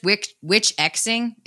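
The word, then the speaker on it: Witch Xing: And you said, do I pronounce this Witch Crossing, by the way? – Yeah, or just witch X-ing is so okay. Like usually X-ing is an abbreviation for crossing.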